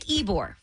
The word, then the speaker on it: Ybor: Like Ybor, for example. – Right. Yeah. It looks like Ybor. – Yeah, it looks like Ybor. – Ybor. Ybor. Right, and there's a bunch of them.